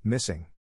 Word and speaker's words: MSSNG: Since 2014, Google has partnered with Autism Speaks in a project called MSSNG. It is pronounced missing.